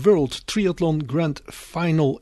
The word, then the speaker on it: World Triathlon Grand Final: Dan hebben jullie 10 leden die gaan meedoen aan de World Triathlon Grand Final in Rotterdam. Zeg ik dat goed zo? – Ja, dat zeg je helemaal goed.